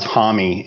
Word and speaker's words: Homme: Of course, I never knew that Josh Homme was pronounced Hommy until just like a couple of years ago either.